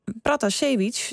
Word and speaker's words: Pratasevich: En ons hier in Hilversum, Protasevich. – Ja, het scheelt één letter, maar het is toch anders? – Het is anders, inderdaad. Dat klopt, zit verschil in. En ja, die Russische uitspraak officieel is Pratasevich.